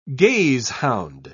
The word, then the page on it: gazehound: British English